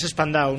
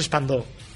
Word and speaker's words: Spandau: Spandau es Spandau, no es Spandó.